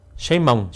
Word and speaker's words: Sheinbaum: Vamos a escuchar a la doctora Sheinbaum. – Porque nadie pronuncien bien, ¿cómo? A ver. – Sheinbaum.